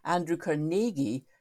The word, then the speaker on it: Carnegie: And the way to remember his pronunciation, Andrew Carnegie, is that his nickname was Naig. So it's Andrew Carnegie.